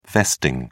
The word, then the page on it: vesting: British English